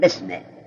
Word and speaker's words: بسمه: در سوره اقرا اونجا دارد اقرا به اسمه، الف را نوشتن لیکن تلفظ نمی کنن، میگن بسمه.